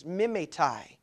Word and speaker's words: mimetai: That word for imitators is mimetai. It sounds like the word for a device that those who are my age and older will probably remember, the old mimeograph.